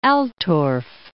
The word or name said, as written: Altorf